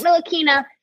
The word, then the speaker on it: Ntilikina: He basically gave the defensive assignment on Trae Young before the halftime to Frank Ntilikina, which I've taken 3 years to learn how to pronounce his last name. So Frank Ntilikina.